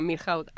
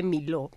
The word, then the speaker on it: Milhaud: que se escribe Milhaud, todos lo decimos Milhaud, hasta que le conoces en persona y te dice que se dice Miló, porque es un músico francés.